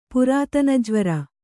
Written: ಪುರಾತನಜ್ವರ

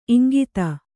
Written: ಇಂಗುದಿ

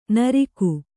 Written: ನುರ್ಗು

